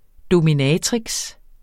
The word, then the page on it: dominatrix: domiˈnæːtʁigs